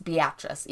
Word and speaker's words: Beatrice: It's pronounced Beatrice, even though it's spelled B-E-A-T-R-I-C-E.